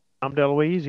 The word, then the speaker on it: DeLuise: Am I saying that right? – Don DeLuise, yeah.